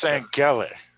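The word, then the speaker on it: Sangailė: We are going to discuss the movie The Summer of Sangal. – Actually, it's Sangale.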